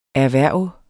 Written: erhverv

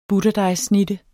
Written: butterdejssnitte

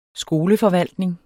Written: skoleforvaltning

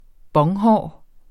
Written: bonghår

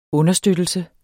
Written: understøttelse

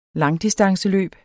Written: langdistanceløb

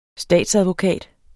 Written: STATSADVOKATEN

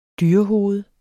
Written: dyrehoved

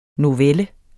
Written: novelle

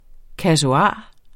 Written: kasuar

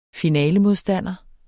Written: finalemodstander

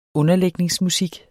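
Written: UNDERLÆGNINGSMUSIK

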